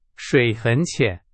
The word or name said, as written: 浅